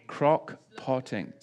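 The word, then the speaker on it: crock-potting: Crock-pocking the... or whatever, that was a Freudian slip. how do we say it? What is it? Crock potting.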